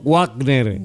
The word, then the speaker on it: Wagner: Vamos pronunciando bien. Mi nombre es Roberto Wagner.